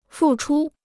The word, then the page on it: 付出: fù chū